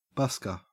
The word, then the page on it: busker: Australian English